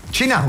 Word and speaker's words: Cinà: Cinà, conosci Cinà? – Ma come no? – Con l'accento sulla.